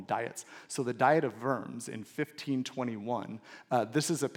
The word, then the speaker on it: Worms: It is not the diet of worms. It was in Germany. It is pronounced worms.